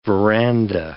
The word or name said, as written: veranda